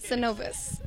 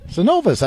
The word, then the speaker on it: Synovus: So tell us, number one, how do you pronounce this correctly? – Synovus. – Synovus. I've been saying it right.